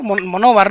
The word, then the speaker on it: Monóvar: Lo he dicho bien, ¿no? Monóvar, ¿no? Monóvar, sí, en Alicante, provincia de Alicante, sí.